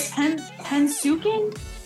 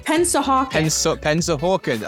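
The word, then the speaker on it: Pennsauken: Mom, how do you pronounce, is it Hensuken? It's not Hensuken. – Pensahawken. Oh, mom knew. She knew immediately. Pensahawken, New Jersey.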